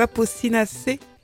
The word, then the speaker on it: Apocynacées: Les Apocynacées? – Apocynacées, oui. Aujourd'hui, j'ai bien prononcé.